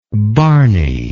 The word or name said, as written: barney